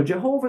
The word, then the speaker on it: Jehovah: The word Jehovah that is standard in Western Christianity is born out of the German way of spelling Yehovah. And then centuries ago, it was Englishized into our common Jehovah. So Jehovah is a pretty reasonable English language-based pronunciation of God's name,